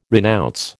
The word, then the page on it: renounce: American English